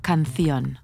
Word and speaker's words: canción: And a special note on the Spanish accent, the Z and the soft C are pronounced with a th sound. So zapatos would be pronounced zapatos, or canción would be canción.